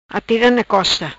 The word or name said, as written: Costa Atira Na